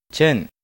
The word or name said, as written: Chen